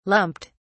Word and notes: lumped: British English

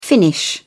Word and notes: finnish: British English

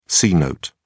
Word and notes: c-note: British English

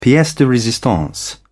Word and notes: piece de resistance: British English

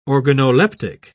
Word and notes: organoleptic: British English

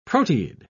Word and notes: proteid: British English

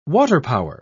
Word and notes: waterpower: British English

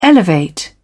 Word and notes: elevate: British English

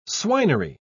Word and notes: swinery: British English

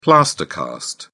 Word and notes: plaster cast: British English

